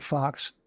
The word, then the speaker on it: Faux: He spells it F-A-U-X, pronounces it Fox.